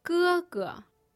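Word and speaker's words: gēge: Gege.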